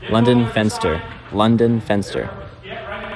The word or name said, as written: Lunden Fenster